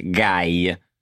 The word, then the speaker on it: gay: Leslie, the word for gay in Italian is just gay with an Italian accent. So it's just guy.